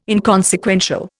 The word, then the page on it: inconsequential: Indian